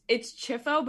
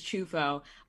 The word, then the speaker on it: Ciuffo: So the real pronunciation is Chufo, but for some reason my family said Chifo because I don't know why, but it's spelled C-I-U-F-F-O. So the correct pronunciation, and in Italy they would say Chufo.